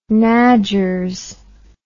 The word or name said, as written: nadgers